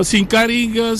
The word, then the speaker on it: Sinkaring: Ah, il y a la fête des moissons, mais c'est une initiation Sinkaring, ou bien je prononce mal? – Non, Sinkaring, c'est dans la région nord aussi.